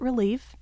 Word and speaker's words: Relevé: And as I was kind of running through all these names, one that stuck out was Relevé. And for the most part, most people pronounce it relief, mm-hmm. and that's fine.